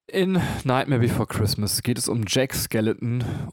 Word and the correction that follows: Skellington: und äh, Skellington. Skellington. Skellington. Ich habe seinen Nachnamen nicht ganz korrekt ausgesprochen.